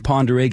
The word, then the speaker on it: Pend Oreille: The county name is actually two words, P-E-N-D, and then O-R-E-I-L-L-E. It's not said the way it looks, right? – Yeah.